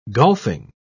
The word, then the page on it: golfing: British English